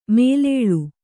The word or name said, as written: ಮೇಲಾಳ್